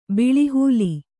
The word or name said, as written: ಬಲೆಹುಲ್ಲು